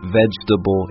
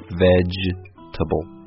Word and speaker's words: vegetable: I guess this word could be challenging because there's four syllables when you look at it, but when we pronounce it, we say it much quicker, so it just sounds like vegetable instead of vegetable.